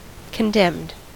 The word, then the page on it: condemned: American English